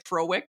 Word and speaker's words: Frowick: Frowick, right? Is that how you say it? Frowick? – That's it.